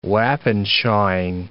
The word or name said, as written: wappenschawing